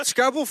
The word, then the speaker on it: Scrabble: Rhymes with rabble. – See, Scrabble for me, it's too much work.